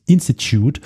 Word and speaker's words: Institute: das muss jetzt englisch ausgesprochen werden, das Institute of Economic Affairs, abgekürzt IEA oder IEA oder so.